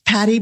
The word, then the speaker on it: Patty: It's Patty with a Y, as Patty mentioned at the beginning, because we both spell our name correctly.